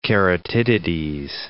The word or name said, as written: keratitides